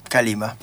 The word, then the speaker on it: Kaleema: ¿Cómo se pronuncia? – ¿Kalima? – Sí, Kalima. – Bien, muy bien. – Perfecto, es fácil. – Es muy fácil. – Kalima.